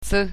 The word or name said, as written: c